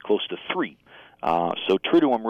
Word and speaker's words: triduum: I think both you and Paul are pronouncing it perfectly, as far as I'm aware. Now, you know, a single word can have many different pronunciations, but triduum is how I've heard it, so okay.